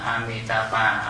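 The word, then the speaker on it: Amitābha: A Di Đà Phật âm Hán Việt á, nhưng mà chữ Hán, chữ phổ thông tiếng phổ thông Hoa là nó không có chữ D, cho nên đọc là chữ M, đọc là Mi Mi Amitabha. Amitabha theo cái chữ phạn,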